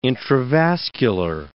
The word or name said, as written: intravascular